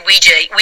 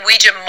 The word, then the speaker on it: Ouija: So people can say Ouija, Ouija, or Ouija, and not be incorrect. You'll hear me saying Ouija more because I'm kind of old school in that sense, yeah.